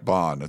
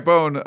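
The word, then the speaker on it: Bohn: If you remember in May, Mike Bone, it's funny, still like local news this morning was like, Mike Bond. It's like, no, I mean, he's been around here for a while. Maybe you should know how to pronounce his name. Mike Bone stepped down, and USC went into search mode for a new athletic director.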